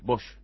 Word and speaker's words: Boix: que se llama Carles Boix, se escribe, Bosch, diríamos, que es su tesis de doctorado en la Escuela de Gobierno de Harvard, mirá vos, en 1994.